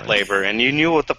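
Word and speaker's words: Patlabor: I'm pretty sure you're saying it wrong. It's mobile police... – Pat Labor. – Yeah, Pat Labor is probably more accurate.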